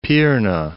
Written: Pirna

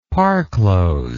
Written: parclose

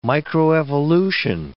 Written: microevolution

